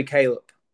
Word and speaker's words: Kallup: It's actually Kallup. – What the? Okay, that's bull spit. How can you spell your name the way that you do? – Exactly, Kallup. Where the hell is the P? I have never heard of a silent P.